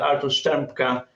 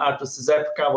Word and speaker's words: Artur Strzepka: My full name is Father Artur Strzepka. And in England, in Wales, we say very often Artur Strzepka, whatever it is correct.